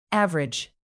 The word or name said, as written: average